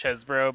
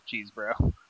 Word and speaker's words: Chesbro: Like, it looks like it should be pronounced Chesbro, but I think I read that it's actually pronounced Cheesebro.